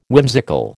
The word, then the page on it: whimsical: American English